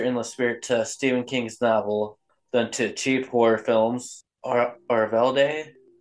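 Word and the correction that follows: Øvredal: the director's name, I don't know how to pronounce it. – Andre Orvdal.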